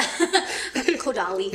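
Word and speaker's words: Kodály: So, I think I always used to say it wrong. Is because it didn't spell like Kodale. – Yeah. Kodali. – Kodali. – Kodali. – Kodali, something like that.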